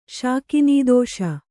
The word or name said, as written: ಶಾಕಿನೀದೋಷ